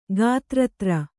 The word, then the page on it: ಗಾತ್ರತ್ರ: gātratra